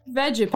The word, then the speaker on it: Vegepygmy: Vegge pygmy? How do you spell that? – V-E-G-E P-Y-G-M-Y. This is how they pronounce it on D&D Beyond. Hang on. – Vegapygmy.